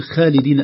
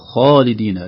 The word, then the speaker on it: خالدين: فلا تقول خالدين أبدا، تقول خالدين، لا تقول غيري غيري.